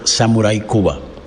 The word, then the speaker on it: Samuray Kuba: y sobre, sobre todo en, la música, eh, desde el 2003 yo me puse como nombre artístico Samurai Cuba.